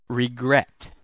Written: regret

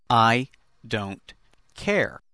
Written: I don't care

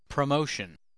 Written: promotion

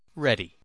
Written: ready

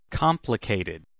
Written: complicated

